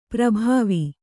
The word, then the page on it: ಪರಿಭಾವ: pari bhāva